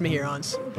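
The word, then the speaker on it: Mehuron's: It's pronounced Mehurons.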